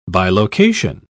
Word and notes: bilocation: British English

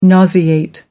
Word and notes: nauseate: American English

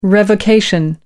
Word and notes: revocation: American English

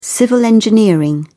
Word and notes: civil engineering: British English

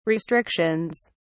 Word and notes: restrictions: British English

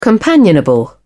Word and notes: companionable: British English

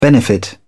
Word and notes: benefit: American English

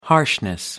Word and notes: harshness: British English

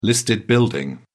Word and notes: listed building: British English

